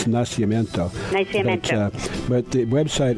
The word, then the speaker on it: Nacimiento: Lake Nacimiento, correct? Am I pronouncing that correct? – Nacimiento, yep.